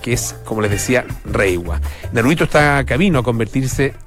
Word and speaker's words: Reiwa: En los signos que se usan en Japón, obviamente que no se escribe así, pero se pronuncia más o menos en esos términos, Reiwa.